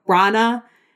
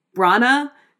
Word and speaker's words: Branagh: So the search for another director was done, and Marvel ultimately settled on Kenneth Branagh. I have been practicing saying his name, Branagh, because beforehand I was going to pronounce it Branagh, and everyone was going to clown me.